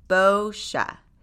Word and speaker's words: boscia: But the correct way to say it is boscia.